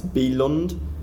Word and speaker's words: Bylund: I can never pronounce his second name right. It's Bylund. It's spelt Bylund on a page, but I think it's a B sound,